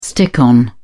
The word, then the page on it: stick on: British English